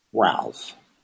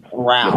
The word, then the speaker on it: Ralph: Ralph. – What's your first name? – Ralph. – Your first name is what? – Ralph. – Miles. Spell that for me. – Ralph. R-A-L-P-H.